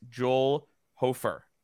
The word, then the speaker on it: Hofer: So let's get to our prospect of the week, and that is Joel Hofer of the – did I say that right? – Yes.